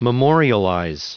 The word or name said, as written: memorialize